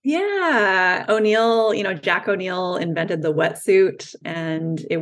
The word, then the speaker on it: O'Neill: Am I saying it right? – I yeah, O'Neill. O'Neill, yeah. yeah.